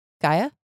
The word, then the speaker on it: Gaia: Gia? How do I say it? – Gaia? Yeah.